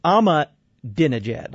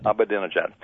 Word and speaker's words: Ahmadinejad: I was wondering if I haven't heard all the press statements on Ahmadinejad. Is that pronounced correctly? – It's I got to look at my notes because I had to write it down myself. Yes, yeah. – It's Ahmadinejad. – Ahmadinejad.